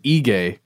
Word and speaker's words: Aegae: In a place called Ege. And again, the internet says I should pronounce it that way. It's spelled A-E-G-A-E.